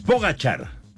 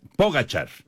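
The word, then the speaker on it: Pogačar: Es Pogacar. Ya nos decía Memo García y ya lo estábamos checando también acá en diferentes páginas. Pogacar, la pronunciación okay, correcta.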